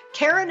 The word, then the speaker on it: Karen: Karen is spelled the normal way, no goofy Y's or Q's, K-A-R-E-N, and Ketelin is spelled K-A-T-A-L-I-N-E.